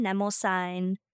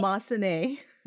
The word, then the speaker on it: Mnemosyne: That is not how you pronounce it. – Nemosyne? – Nemosyne. – Was it? – Yes, our Babble Bomb. – Okay, I can't remember. – Nemosyne. Yeah, I think it was Nemosyne.